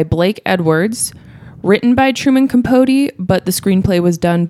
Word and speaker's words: Capote: Capote.